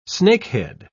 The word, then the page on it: snakehead: British English